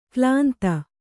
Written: ಕಾಲಿಂದಿ

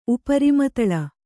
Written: ಉಪರಿಮತಲ

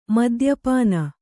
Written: ಮದ್ಯಪಾನ

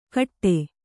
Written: ಕಟ್ಟೆ

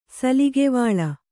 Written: ಸಲುಗೆವಾಳ

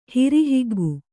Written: ಹಿರಿಹಿಗ್ಗು

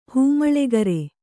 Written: ಹಿಮ್ಮೇಳಕಾರ

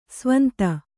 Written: ಸ್ವಾಂತ

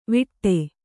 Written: ವಿಟ್ಟೆ

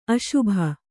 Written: ಅಸುಬು

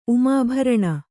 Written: ಉಮಾಭರಣ

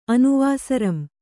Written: ಅನುವಾಸರಂ

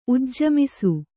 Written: ಉಜ್ಜಮಿಸು

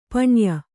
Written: ಪಣ್ಯ

